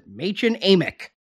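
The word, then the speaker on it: Mädchen Amick: Machen Amick.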